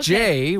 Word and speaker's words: Jay: My name is Jay, which rhymes with...